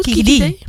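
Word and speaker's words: Kiki Dee: Zeg ik dat goed? – Kiki D. – Kiki D. – Kiki D. – Kiki D. Kiki D. Als in Kikaboe, maar dan Kiki D.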